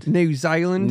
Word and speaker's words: New Zealand: You said it wrong though. – New Zealand.